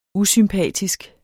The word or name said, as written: usympatisk